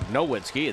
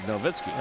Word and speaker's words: Nowitzki: I'm impressed that you say the W as a V, Jim. Tim, that's very good. That's exactly the way it should be pronounced. – Well, it's not Wagner. Dirk. It's not Dirk Nowitzki. It's Dirk Nowitzki.